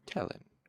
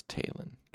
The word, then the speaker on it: Tehlin: Yep, it's Talin. God damn it, Patrick Rothfuss. Okay, so it's spelled T-E-H-L-I-N, so I was pronouncing it Tellin, but apparently it is Talin, and so it is also Talu, not Telu.